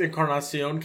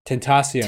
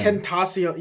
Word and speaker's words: Tentacion: I always say it wrong and I say XXX Encarnacion because it's the first thing that pops. – That is not even wrong. – Phonically. I know it's wrong. But Tentacion. Tentacion.